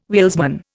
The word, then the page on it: wheelsman: Indian